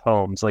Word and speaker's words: Holmes: But here, that that name's pronounced Holmes, like kind of like the home you live in.